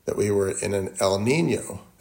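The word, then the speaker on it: El Niño: In fact, if you want to remember that, El Nino rhymes with San Diego.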